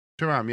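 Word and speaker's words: Thuram: That's his name, isn't it? No. – Yeah, we'll go with that. Pronunciation isn't going to be great on this spot. – It's Kefram, Kefram. – Is it Turan, though? – Turan, yeah. Kefram Turan. – Kefram Turan.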